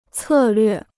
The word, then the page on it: 策略: cè llüè